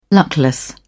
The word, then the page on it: luckless: British English